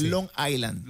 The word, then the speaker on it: Long Island: No se pronuncia, porque Island se debería pronunciar, ¿no? Pero no. – Island. – Island, sí, es como que no, es como que te comes la S. – Long Island.